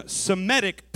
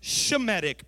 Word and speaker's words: Semitic: And that's why you might call them Semitic people, but it's actually pronounced Shemitic people.